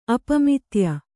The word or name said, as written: ಅಪಮಿತ್ಯ